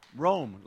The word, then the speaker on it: Rome: Rome, how do you pronounce it? Rome? Rome, like the city of Rome?